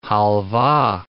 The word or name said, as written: halva